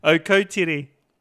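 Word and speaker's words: Akrotiri: Okotiri. And they pronounce it Al Cowtree, and I was like, Al Cowtree? Where the hell's Al Cowtree? Oh, you mean Okotiri.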